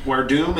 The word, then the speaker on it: Werdum: Werdum is known for jiu-jitsu. It's exactly how you pronounce it.